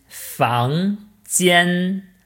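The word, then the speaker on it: Fang Jian (房间): Ich würde sagen, Zimmer ist Fang Jian, also Fangjian. Fang Jian. – Ja, ich finde, du hast es sehr gut ausgesprochen.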